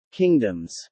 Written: kingdoms

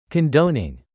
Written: condoning

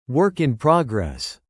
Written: work in progress

